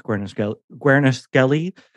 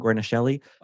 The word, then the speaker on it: Guarnaschelli: In my never-ending journey through the world of reality TV, have recently decided that Alex Guarnaschelli, I believe is how you say her last name, Guarnaschelli, of the Food Network, is my favorite celebrity.